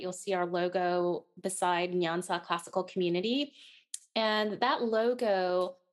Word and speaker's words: Nyansa: Nyansa. – Is that correct? Nyansa.